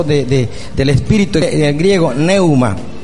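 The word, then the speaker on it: pneuma: En el griego, eso sí ya lo he escuchado, algunos que hombres de Dios que dice que es neuma, P-N-E-U-M-A, y se pronuncia neuma,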